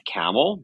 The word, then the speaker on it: Kamil: My name is Seth Kamil. The last name is spelled K-A-M-I-L.